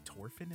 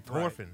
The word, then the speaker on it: Thorfinn: Oh, they say Thorfinn in the dub? – In the sub, dude, whenever, like, in the original audio. – Oh. – They don't pronounce the H. They say Thorfinn. – Right. Thorfinn.